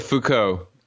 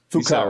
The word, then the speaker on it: Foucault: First question, Foucault, how do you say his name? I've read it a million times. How do you say it? – No, Foucault. – Foucault, right.